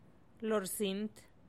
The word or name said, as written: lorsint